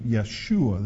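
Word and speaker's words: Yeshua (ישוע): And sometimes you will hear the name Yeshua. That's the Hebrew pronunciation of Jesus' name.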